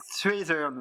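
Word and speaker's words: Strathairn: Euh, ce serait pas Strathern Strathern, oui. je pense que ça doit être ça, Strathern.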